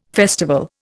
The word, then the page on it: festival: Indian